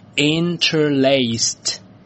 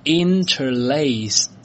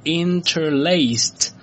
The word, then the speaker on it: interlaced: 那么要注意的是呢，这个词呢加上 d 之后呢，它是念作，来跟我一起念，它念作 interlaced， interlaced，而不是 interlaced。我之前呢也一直认为应该是念作 interlaced 这样的吧，可是呢我在准备的时候呢一直查了一下，查了好几个字典呢，发现都是念作 interlaced，所以呢我确定应该是以这个 t 结尾。